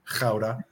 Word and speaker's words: Gouda: Gouda, right? to pronounce it as it should be pronounced.